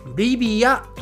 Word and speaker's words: リビア: リビアと日本語では発音をします。えー、これもね、結構似ていますよね。そしてこのリビアの首都。リビアの首都は英語だとはい。